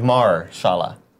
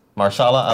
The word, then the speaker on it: Mahershala: Yeah, it's like saying like Marshala. Mahershala Ali. – I think you're not giving one of the syllables enough credit there.